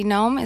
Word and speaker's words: Noem: I find Governor Kristi Noem, is that how you mm-hmm. pronounce her last name? – Yes, it is.